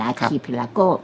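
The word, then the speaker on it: archipelago: อาคีพิลาโก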